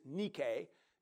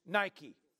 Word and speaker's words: Nike: Okay, actually it's Nike, is how it's pronounced in the Greek, but we always know it as on shoes, Nike.